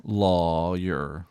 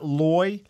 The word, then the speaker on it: lawyer: How do you pronounce the first syllable of the guy who tries to throw you in jail? – Lawyer. Lawyer. – So law like flaw. It's not loy, lawyer, like boy? – Lawyer.